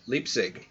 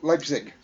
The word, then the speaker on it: Leipzig: Composer-conductor born in 1813 in Leipzig. That's how you pronounce that. – Yep, Leipzig.